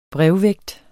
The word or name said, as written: brevvægt